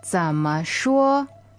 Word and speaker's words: 怎么说: All right, remember 怎么, that 怎 goes low, and 说 is first tone, flat and high. – That's right. 怎么说?